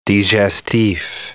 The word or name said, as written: digestif